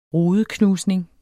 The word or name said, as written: rudeknusning